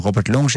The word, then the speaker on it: Robert Long: Je komt namelijk ook nog naar Orpheus met liedjes van Robert Long, zeg ik dat goed? – Ja, klopt.